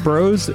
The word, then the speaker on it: Bros: Bros is pronounced like Ross.